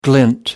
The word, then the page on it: glint: American English